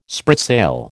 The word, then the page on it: spritsail: American English